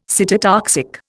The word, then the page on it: cytotoxic: Indian